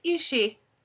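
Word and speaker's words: aos sí: That's another one that's spelled funny and pronounced differently. You want to say AOSC, it's AOSSI, but it's Ishi, apparently.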